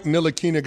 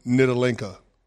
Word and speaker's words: Ntilikina: So when Frank Ntilikina got drafted by the New York Knicks, I called him Nitalinka. I tried to pronounce it like it was spelled, and that was a mistake.